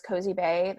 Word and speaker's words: Cozibae: I'm on there as Cozibae.